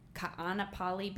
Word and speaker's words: Ka'anapali: You are in the process of acquiring now you're going to have to pronounce Ka'anapali Beach. – Perfect. That was perfect.